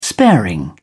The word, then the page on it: sparing: British English